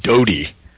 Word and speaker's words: Dody: the name Doty, although it's spelled D-O-D-Y, conveniently.